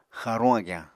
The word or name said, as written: xaaruñ agyañ